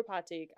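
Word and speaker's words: Pathik: It's Patik, right? That's how you pronounce it? – Okay. Yeah. – Guru Patik, meditating on top of the tower.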